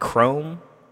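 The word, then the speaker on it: Chrxme: Produced by, look like Chrome. Is how you would pronounce that. It's a X, C-H-R-X-M-E. – All right. – And 28 Morgan, 28 M-O-R-G-N.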